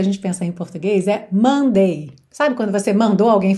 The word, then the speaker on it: Monday: Sendo que a sílaba tônica tem que ser no começo, não Monday, mas sim Monday, Monday, ok? Mandei bem. Alright? É bem parecido esse som. Mon, mon, tá? Ele é bem nasal.